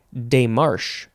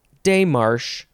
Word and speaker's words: démarche: The next word is démarche, or démarche, or démarche. D-E-M-A-R-C-H-E, with or without an accent on the first E.